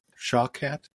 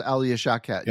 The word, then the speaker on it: Shawkat: And my, I don't know how to pronounce her name, Alia Shawkat? – Shawkat. It's Alia Shawkat.